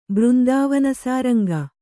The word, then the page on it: ಬೃಂದಾವನಸಾರಂಗ: Kannada